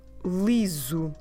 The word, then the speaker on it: liso: Liso. Let me spell it for you. L-I-S-O.